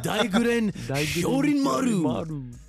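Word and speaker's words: Daiguren Hyōrinmaru: But don't be asleep on Hitsugaya's Daijirin Hero. – I can't say that shit. I fucking knew you wasn't gonna be able to pronounce that shit. It's the Daijirin Shorin Maru.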